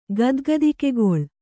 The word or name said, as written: ಗದ್ಗದಿಕೆಗೊಳ್